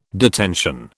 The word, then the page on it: detention: British English